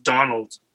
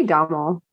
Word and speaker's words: Domhnall: I think it's just Donald, honestly, just spelled in the Irish way. – Oh, Domhnall? I say Domhnall. – Domhnall? – Yeah. – Yeah. – It sounds like Donald.